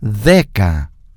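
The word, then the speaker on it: Deca (δέκα): Hay que tener cuidado con ciertas pronunciaciones, que cuando decimos el de, deca, y palabra pronunciada con este, como que se pone la lengua entre medio de los dientes, parecido al del idioma inglés, de, deca.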